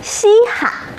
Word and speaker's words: 稀罕: 标红的这个字读罕，罕见的罕，在这个词中读轻声，稀罕。